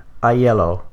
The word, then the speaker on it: Aiello: This viewing, I was very delighted by the performance by Danny Aiello. That's his name and that's how it's pronounced.